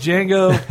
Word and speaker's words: Django: The D is silent. Django.